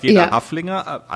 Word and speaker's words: Haflinger: Also bei uns in Österreich wird das Wort Haflinger mit langem A ausgesprochen und deswegen hat es mich gewundert, dass ihr das so kurz aussprecht als Haflinger.